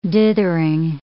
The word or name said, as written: dithering